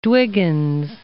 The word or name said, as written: Dwiggins